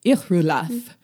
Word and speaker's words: Irulath: Irulath. R is rolled, pronounced by pursing lips to say ooh, but say E instead. Last syllable rhymes with swath.